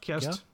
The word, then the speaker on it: Kerst: Weihnachten auf Niederländisch ist doch Ker Kerst, ne? Kerst. Kerst. – Kerst.